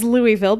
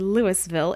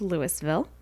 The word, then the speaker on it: Louisville: City, it says Louisville, but it's actually Louisville. It's in Colorado, so it's pronounced Louisville.